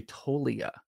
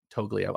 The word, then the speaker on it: Toglia: It's actually Tolia. The G is silent with Michael Tolia. So I get to have a very exciting minor league event. I thought you said Toglia.